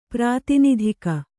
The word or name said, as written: ಪ್ರಾತಿನಿಧಿಕ